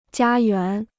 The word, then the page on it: 家园: jiā yuán